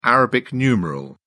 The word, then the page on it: arabic numeral: British English